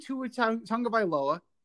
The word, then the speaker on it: Tua Tagovailoa: But knowing Tua Tagovailoa, hey, I said it right.